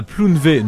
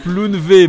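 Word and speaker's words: Plounévez: Donc, Humifère, qui est une société qui se trouve à Plounvé. Ne prononcez pas le Z. J'ai eu une remarque tout à l'heure. Presque. Plounvé Moedec, qui se trouve donc dans le.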